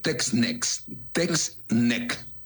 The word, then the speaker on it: Text Neck: Está el famoso Text Neck. Text, ya sabes. – Sí, de test. – Ajá. NECT, que es cuello tecnológico. ¿Lo pronuncié bien, Doc? – Sí, claro.